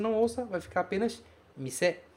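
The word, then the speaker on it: mise (店): A pronúncia deste kanji é mise. Tá aqui em hiragana, tá aqui também em romaji.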